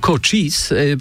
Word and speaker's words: Cochise: Oni tak akcentują się, zespół Cochise.